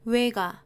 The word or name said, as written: 외가